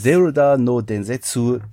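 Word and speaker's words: Zeruda no Densetsu (ゼルダの伝説): Zeruda no Densetsu, Kamigami no Toraifusu.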